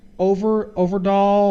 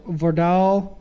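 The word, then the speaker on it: Øvredal: Yeah, and I mean, you know, the director of this movie is actually Andre Overdahl or Verdahl, because I think the O is not pronounced. So Verdahl, Andre Verdahl.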